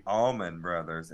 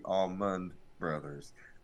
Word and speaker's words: Allman Brothers: Money Mike says, I was today years old when I realized the band was called the Allman Brothers and not the Almond Brothers. I've been saying it wrong for years.